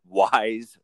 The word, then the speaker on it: Wise: And you can find me now at Wise Rye. So it's like wise guy, but it's my name. W-I-S-E.